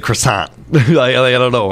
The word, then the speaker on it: croissant: In French, it's croissant. In English, it's pronounced croissant. – With the T. – Yeah. Well, in a sant. Like the sant. You got to get in there. croissant.